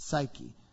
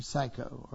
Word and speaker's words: psyche: It's psyche, or psycho, or psyche, or depending on how you say it.